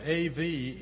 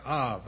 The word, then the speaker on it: Av: The Hebrew word for father is spelled A-V in English. And it's pronounced something like Av, Av, Av, Av, Av.